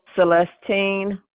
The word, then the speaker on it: Celestine: Celestine Moore.